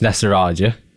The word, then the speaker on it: Nesarajah: My full name is Tobias Nesarajah.